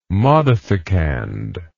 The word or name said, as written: modificand